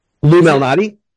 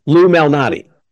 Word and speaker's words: Lou Malnati: I will say, can you pronounce it for me? Because I think I might have butchered it just now. – Lou Malnati? – Lou Malnati's? – Lou as in Lewis. Lou Malnati. – Lou Malnati, okay.